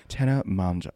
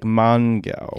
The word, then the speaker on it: Tana Mongeau: Tana Mongeau. When you see it written down, you're like Mongo. – Yeah, it looks like Mongo.